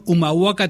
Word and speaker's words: Humahuaca: Bueno, Mi cielo en el fondo del aljibe, todos los viernes a las 19:30 horas en el espacio Callejón Humahuaca 3759.